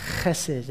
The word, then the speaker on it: Chesed: Chesed. Can you say that? Chesed. That's right. You can do that. Just spit comes out. You're saying it right.